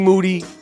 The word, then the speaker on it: Moody: A few have asked about G. Moody, last name rhymes with duty, and where has he been and all that stuff.